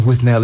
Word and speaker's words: Withnail: Yes, um, and there's some confusion at which should address this thought about how the character of Withnail is pronounced. Because uh, when the film is referred to by its director and its publicity and so forth, it is Withnail.